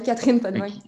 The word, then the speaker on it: Catherine Potvin: Je prononce bien le nom? – Oui, oui, ouais, Catherine Podvin.